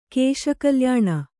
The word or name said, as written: ಕೇಶಕಲ್ಯಾಣ